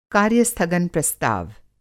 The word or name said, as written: कार्य-स्थगन प्रस्ताव